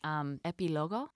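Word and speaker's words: Epílogo: It's Epilogo. Is that how did I pronounce that right? So it's pronunciation. Yes.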